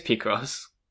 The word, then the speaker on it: Picross: I see Playcross puzzles everywhere. – You've been playing Pokemon Picross? – Picross, yeah. – Picross? Picross? – Picross? – I think it's Picross.